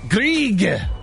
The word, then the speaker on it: Grieg: Grieg. What is the address there? Grieg. – Grieg. – Grieg. – How do you spell it? – G-R-I-E-G.